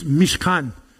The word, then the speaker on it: Mishkan: It's Mishkan. It's how you pronounce this in the Hebrew.